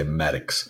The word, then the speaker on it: Maddox: Down here, the toilets flush the other way, and you say Maddox. – I love it. – Rhymes with addicts.